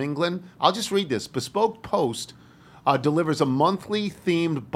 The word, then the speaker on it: Bespoke: I think it's Bespoke. – Yes.